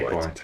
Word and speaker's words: Guaita: Is it, how do you say it? Guaita. – Yeah, Guaita. You probably say it better than I do.